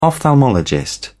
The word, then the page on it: ophthalmologist: British English